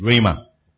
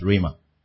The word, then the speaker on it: rhema: The word of God there was rhema, which is rhema, pronounced rhema.